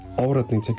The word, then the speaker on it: Оратница: Разговаряме с актьора Илиан Марков, след като чухме едно изпълнение на група Оратница, както той така ме поправи, че е точното ударение.